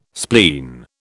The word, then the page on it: spleen: British English